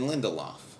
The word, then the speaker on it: Lindelof: Damon Lindelof. – Lindelof? – Lindelof.